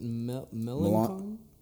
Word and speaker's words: Melancon: Melancon? – Yes.